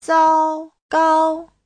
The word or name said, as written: zao1gao1